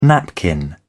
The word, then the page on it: napkin: British English